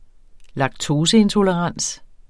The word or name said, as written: laktoseintolerans